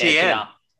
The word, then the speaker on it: Etienne: How do we say his last name? – Etienne. – It's Etienne.